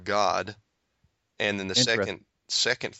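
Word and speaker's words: Gad: which is pronounced God in the Hebrew language.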